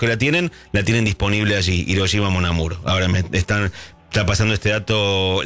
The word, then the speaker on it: Hiroshima Mon Amour: Hiroshima Mon Amour. – Hiroshima Mon Amour.